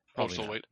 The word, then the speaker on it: Postlethwaite: So in this movie, we have Jeff Goldblum coming back as Ian Malcolm, Julianne Moore showing up as Sarah Harding, Pete Postlethwaite. Did I say that right? – Pulse of Weight, but yeah, that's Pulse close. Weight, very close. Very close.